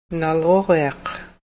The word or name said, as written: nalruriaq